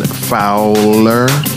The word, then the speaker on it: Fowler: I got a name wrong. It's Ryan Fowler. Fowler? – Like Ricky Fowler. – Like Fowler. – Oh, Fowler.